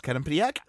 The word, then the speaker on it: Quinnipiac: I'm sorry, is it pronounced Quinnipiac? – Yeah.